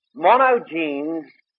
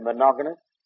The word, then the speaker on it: monogenes: Now, in the oldest Greek manuscripts, that is, back to the 3rd and 4th centuries, they all have the translation Monogenes Theos. Some people pronounce that word monogamous, but that's not the correct pronunciation of it,